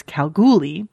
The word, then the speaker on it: Kalgoorlie: Anyway, despite the slight mispronunciation in the episode, emphasis on the middle syllable, ignore the random R, so it's Kalgoorlie.